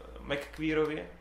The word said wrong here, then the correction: McQuarrie: McQuarrie, McQuarrie.